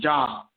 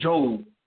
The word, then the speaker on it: Job: And when God put on her spirit, she was talking about I used to say, because I couldn't pronounce right, but I said Job, but it's Job.